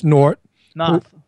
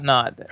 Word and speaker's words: Noth: The Cutting Room is a nightclub that is part owned by Chris Noth. – Noth. – Noth. – N-O-T-H, Noth. – Noth. – It's not Noth. – How do you say Noth? – It's Noth, Chris Noth.